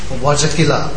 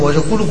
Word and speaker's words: Vajrakila: Instead of saying Om Vajrakila Kili Soha, he said Om Vajrakula Kula Soha.